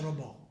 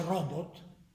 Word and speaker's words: robot: non si dice robot, si dice robot.